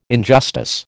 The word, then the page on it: injustice: American English